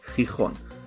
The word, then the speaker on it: Gijón: Und so kam es zur Schande von Gijón. Wird so ausgesprochen, ich habe es nachgehört.